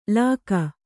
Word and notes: ಲಕ್ಕು: kannada

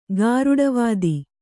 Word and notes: ಗಾರುಡವಾದಿ: Kannada

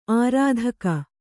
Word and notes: ಆರ್ಥಿಕ: ārthika

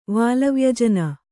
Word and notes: ವಾಲವ್ಯಜನ: kannada

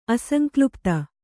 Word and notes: ಅಸಂಕ್ಲುಪ್ತ: kannada

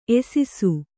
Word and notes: ಎಸಿಸು: Kannada

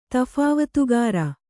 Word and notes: ತಫಾವತುಗಾರ: Kannada

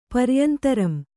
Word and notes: ಪರಿಯಂತರಂ: Kannada

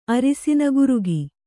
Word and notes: ಅರಸಿನ ಗುರುಗಿ: arasina gurugi

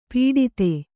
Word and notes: ಪುಟಿತ: puṭita

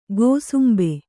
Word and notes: ಕುಸುಂಬಾ: kusumbā

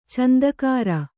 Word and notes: ಚಂದಕಾಱ: Kannada

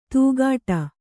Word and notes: ತೂಗಾಟ: tūgāṭa

